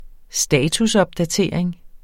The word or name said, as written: statusopdatering